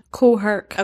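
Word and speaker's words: Kool Herc: Cool Herc.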